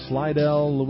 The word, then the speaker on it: Slidell: He is pastor at Lamb of God Lutheran Church in Slidell, Louisiana.